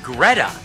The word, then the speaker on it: Greta: When we give you the word, text Greta. Rhymes with feta, like cheese. G-R-E-T-A.